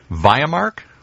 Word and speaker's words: Viamark: That's us, and we're back with Steve Facenda from, make sure I say it right, Viamark? Like Viagra.